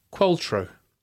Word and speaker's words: Qualtrough: Qualtro. – Okay. – R.M. Qualtro. – Yeah, which is spelled Qualtrough. – Like Susie Quattro.